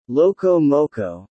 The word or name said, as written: loco moco